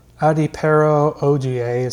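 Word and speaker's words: Adepero Oduye: Adepero Odie is how it's pronounced.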